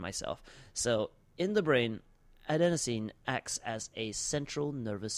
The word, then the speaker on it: adenosine: A chemical, did I pronounce that right? Adenosine? Yeah, adenosine, guys.